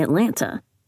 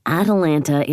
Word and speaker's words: Atalanta: Atalanta. Not named after the city. – Because that would be Atlanta. Atalanta is a huntress from Greek myth. I'm sure you hear that mistake a lot.